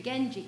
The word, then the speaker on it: Genji: Genji. Is it a hard G? – It is, I'm sorry to say.